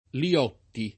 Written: Liotti

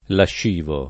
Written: lascivo

